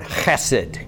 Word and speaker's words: chesed: There's a Greek, Hebrew word here, sorry, in the Old Testament for this steadfast love of God, which is a guttural word. It's kind of fun to pronounce if you like to use your throat. It's the Hebrew word chesed. Can we say that together? But you got to reach deep. Chesed.